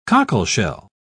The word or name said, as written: cockleshell